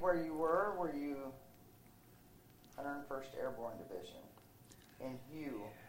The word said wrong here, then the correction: Hue: Way. – Way, excuse me. It's spelled H-U-E.